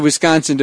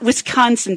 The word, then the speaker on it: Wisconsin: We went up to Wisconsin to visit my dad. – And notice that's not Wisconsin, people. – It's Wisconsin. Wisconsin the way he no, pronounces no, it's Wisconsin.